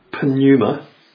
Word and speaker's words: pneuma: The Greek word pneuma, that's spelt with a P, pneuma.